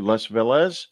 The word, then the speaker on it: Les Velez: We're talking to Les Velez.